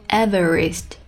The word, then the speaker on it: Everest: I would climb Everest.